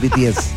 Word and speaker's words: BTS: Iba a decir pies, pero es BTS. Lo dije bien, ¿no? Sí, lo dije bien. – Muy bien, vamos con esa rola de BTS.